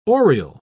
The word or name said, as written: oriel